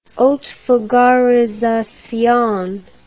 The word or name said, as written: haute vulgarisation